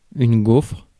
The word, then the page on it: gaufre: /ɡofʁ/; /ɡɔfʁ/